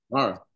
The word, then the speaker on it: Kamara: Kamara.